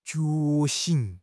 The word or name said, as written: 觉醒